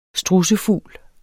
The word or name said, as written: strudsfugl